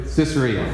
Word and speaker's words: Caesarea: Caesarea, thank you.